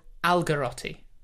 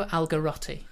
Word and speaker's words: Algarotti: Count Francesco Algarotti. – Algarotti. – Count Francesco Algarotti.